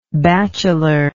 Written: bachelorly

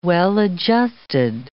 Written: well-adjusted

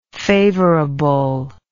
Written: favorableness

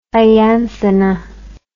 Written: ianthina

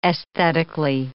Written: aesthetically

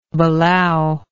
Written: balao

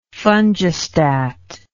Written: fungistat

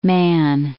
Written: Mann

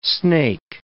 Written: snake

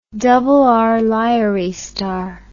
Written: RR Lyrae star

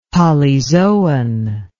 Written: polyzoan

